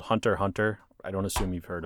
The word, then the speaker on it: Hunter x Hunter: And the worst part is you see it written out as Hunter x Hunter. The X is silent.